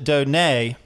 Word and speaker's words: Donay: So this is how we spell your name, yet we pronounce it Donay. – Right. – The T is silent.